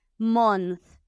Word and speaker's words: month: Entonces nos tenemos que asegurar que pronunciamos esta TH. Month month. No podemos decir month y comernos la TH, ni month. No, tiene que ser perfecto para que nos entiendan.